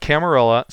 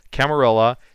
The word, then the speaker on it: Camarilla: We are here today to talk about Camarilla, so let me preface it by saying I've been pronouncing it Camarilla for like 25 years now, so I know that there are people out there who firmly believe that it should be pronounced Camarilla, but I just can't do it, guys, so it's going to be Camarilla out of me. Craig will have the option to pronounce it however he wants, but I just can't do the Spanish L's.